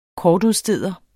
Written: kortudsteder

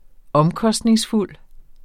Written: omkostningsfuld

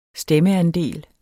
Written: stemmeandel

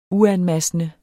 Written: uanmassende

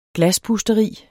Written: glaspusteri

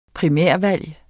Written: primærvalg

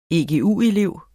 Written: egu-elev

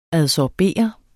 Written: ADSORBEREDES

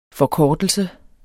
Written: forkortelse

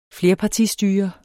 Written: flerpartistyre

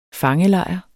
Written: FANGELEJR

